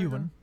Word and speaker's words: Ewan: Ewan.